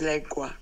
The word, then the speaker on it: lengua: But how it's correctly pronounced is lengua with an E.